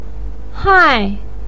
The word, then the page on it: hai: 4